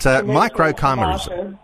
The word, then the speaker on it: microchimerism: My pronunciation of your science, microchimerism, and I said microchimerism. That had a little bit more romantic feel to it to me, but microchimerism.